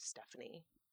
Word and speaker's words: Stefani: Her name is, it's spelled Stefani. But like I think the American way to say it is Stephanie. It's like her name ends with an I.